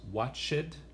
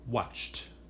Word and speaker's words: watched: No decimos watched, sino watched. Como si tuviera un T al fin de la palabra en el pasado.